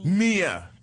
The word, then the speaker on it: Mia: I've been calling her Maya for the longest, and she just pronounced it in an introduction a moment ago, and I just found out that I've been mispronouncing it this whole time. – Right. Okay. Hi, guys. I'm Mia Leilani. – Mia. – M-I-A, Mia.